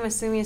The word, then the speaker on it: Masumi: My name, Masumi, is spelled M-A-S-U-M-I.